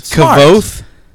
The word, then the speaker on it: Kvothe: It's Kvothe. Did we get close to it? What did we say? Kvothe? – Yeah.